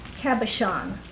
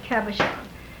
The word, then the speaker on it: cabochon: And when Natalie's father was here, and he was down in the lapidary, and he took exception at my English pronunciation of the word cabochon. And I do not have a good nasal French, but cabochon.